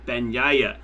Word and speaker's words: Bagnaia: There's no bag in that name. From someone that actually speaks Italian was running it through me in an email saying, listen, this is how we need to pronounce it. So I believe it's more like, oh, so Bagnaia is how I will try to say it.